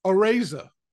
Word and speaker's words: Araiza: Is that how you say it? – Araiza. – Araiza. And you would think I would be Araiza.